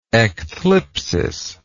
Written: ecthlipsis

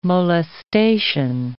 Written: molestation

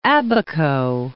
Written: Abaco

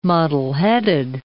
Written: muddleheaded

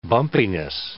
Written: bumpiness

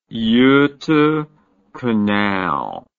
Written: Gota Canal

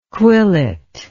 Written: quillet

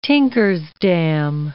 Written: tinker's dam

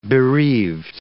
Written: bereaved

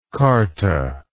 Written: charta

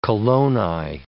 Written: coloni

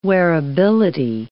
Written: wearability

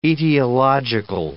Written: etiological

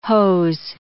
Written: hose

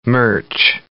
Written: merch

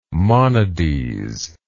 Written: monades

